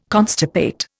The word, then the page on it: constipate: Indian